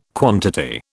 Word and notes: quantity: British English

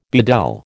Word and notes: bedel: American English

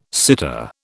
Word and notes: sitter: British English

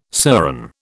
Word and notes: serran: British English

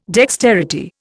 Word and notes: dexterity: Indian

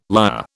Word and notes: liar: British English

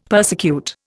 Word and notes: persecute: Indian

